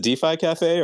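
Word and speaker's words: def1cafe: And 0x, do you pronounce it DeFi Cafe or Def1? I wasn't sure. – Yeah, good question. DeFi Cafe.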